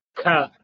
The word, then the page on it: kh: Sanskrit